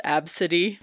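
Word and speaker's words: ABCDE: And it's pronounced Absidy. Absidy, yep.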